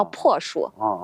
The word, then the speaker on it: 朴树: 黄果树，它的学名叫朴，就是那个朴素的朴，它是个多音字，念树名叫朴树。